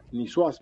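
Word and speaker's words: niçoise: Yo en un menú, acá mismo, hace poco, tuve que sacar la palabra nisuaz, porque se escribe nicoise con una cedilla.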